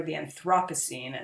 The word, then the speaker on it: Anthropocene: the Anthropocene, or the Anthropocene, as I've heard it pronounced in England.